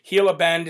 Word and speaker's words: Gila Bend: Yes, it's Gila Bend, Gila Bend. It's actually pronounced with an H.